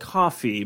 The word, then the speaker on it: Kofi: It's called Coffee, but it's spelled K-O-F-I.